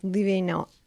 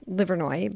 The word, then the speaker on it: Livernois: So that's it's probably meant to be pronounced Livinois, but it's Livernois because no one in Detroit appreciates that it was founded by, you know, French fur trappers.